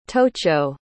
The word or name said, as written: tocho